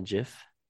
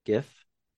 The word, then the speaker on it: GIF: Is it a GIF or is it Jiff? – I call it a GIF.